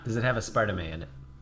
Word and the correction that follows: aspartame: That's no aspartame.